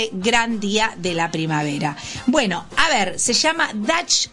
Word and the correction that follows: Dachshund: ¿Cómo se dice esto? A ver, amor... ¿Cómo lo lees vos? Dachshund. – Dachshund. – Dachshund es el perro salchicha.